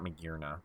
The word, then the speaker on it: Magearna: I still can never remember how to pronounce that. – It's Japan's was Magearna, and then we got Magearna.